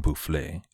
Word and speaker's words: Boufflers: Marshal General Boufflers, and thanks to those of you who corrected my pronunciation of Boufflers, the marshal was securing France's position all along the Rhine.